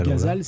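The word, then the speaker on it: ghazal: Il y a donc la cestine, il y a le Gazo, je ne sais pas comment prononcer ça en français. – Gazal, c'est ça. Je crois qu'on prononce à la française.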